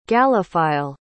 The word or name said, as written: gallophile